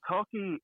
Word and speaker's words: Toelke: I think and Tolkey, I think Tolkey, if anyone wants to look, is T-O-E-K-L-E, right? – Yes. Yes. – Yeah, yeah. It, phonetically, you wouldn't probably couldn't have guessed it without like spelling it. Cause, yeah, T-O-E-K-L-E. No, I still spelled that right. – Yeah. T-O-E-L-K-E.